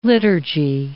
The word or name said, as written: liturgy